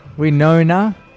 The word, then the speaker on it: Winona: Winona.